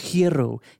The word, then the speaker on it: gyro: First of all, if you want to be all technical about it, it's pronounced hiero, or whatever, something to that. Some Greek girl told me that one time. She kept picking on me because I kept calling it a gyro. She's like, it's hiero, it's hiero.